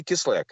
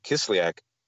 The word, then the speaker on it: Kislyak: Then Russian ambassador Sergei Kislyak, apparently that's how you pronounce his name properly.